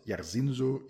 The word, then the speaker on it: Jairzinho: Uh, in which I break down his fight against Jairzinho Rozenstruik, and that is the way you pronounce it, Jairzinho, Jairzinho Rozenstruik. He's Dutch.